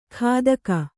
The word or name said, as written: ಖಾದಕ